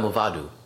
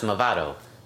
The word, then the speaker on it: Movado: Oh, because you said, like, D-O, like, dude, yeah. – Like, I do. – Movado, yeah. And then she's like, no, it's Movado.